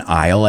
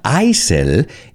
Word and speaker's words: aisle: It's not aisel, it's aisle. And I always remind my students that pasillo en el avión, you say aisle.